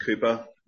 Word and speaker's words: Cowper: These are the words of the poet and hymn writer, William Cowper, spelled Cowper, pronounced Cooper.